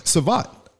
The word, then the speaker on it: savate: He's also a master of savate.